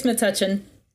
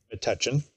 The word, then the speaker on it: Metuchen: In 1981, he was transferred to a New Jersey diocese and became bishop of, how do you say it? – I think it's Metuchen. – Metuchen, all right.